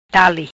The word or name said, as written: Dale